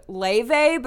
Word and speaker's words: Laufey: pod I'm obsessed with this girl named Laufey, but it's spelled L A U F E Y.